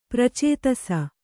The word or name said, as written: ಪ್ರಾಚೇತಸ